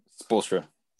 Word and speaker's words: Spoelstra: So I think with their coaching staff, I think Eric Spoelstra, how do you say his last name? – Spoelstra. – Okay. Almost.